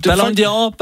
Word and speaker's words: Ballon d'Or: Det var fordi, du sagde åbenbart, jeg undrer mig over, at nyhedsverdenen blev ved med at sige, Ballon Dior er modehuset blevet hovedsponsor. Dior. Nå. Altså, det er ikke mig. – Nå. – Det er fordi, at nå. Folk synes, du... – Ballon d'Or, Ballon d'Or.